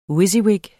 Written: WYSIWYG

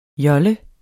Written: JOLLERNE

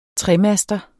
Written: tremaster

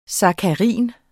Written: sakkarin

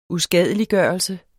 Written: uskadeliggørelse

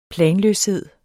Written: planløshed

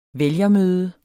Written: vælgermøde

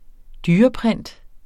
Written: dyreprint

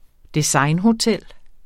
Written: designhotel

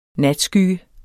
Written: natskygge

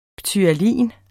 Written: ptyalin

